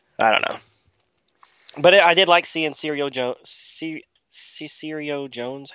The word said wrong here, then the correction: Cyrano: How do you say his name? – Cyrano. – Cyrano Jones. – Like Cyrano de Bergerac.